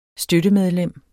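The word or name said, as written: støttemedlem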